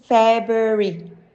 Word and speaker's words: February: Right pronunciation, February. Common mispronunciation, February. English has a lot of words with silent R, but not usually after the letter B, leading a lot of people to pronounce this month incorrectly.